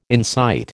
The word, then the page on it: incite: American English